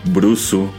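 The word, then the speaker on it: Brusu (ブルース): Pero bueno, este es un error derivado del hecho de que pues este género musical, el blues, se pronuncia Brusu en, en Japón, o por lo menos así es como se escribe en japonés en katakana.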